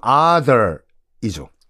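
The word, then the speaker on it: Arthur: 큰아들 이름이 이제 아서인데 이 아서라는 아서라가 아니라 뭐 정확한 발음은 other이죠. 그냥 아서라고 할게요.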